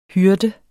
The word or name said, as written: hyrde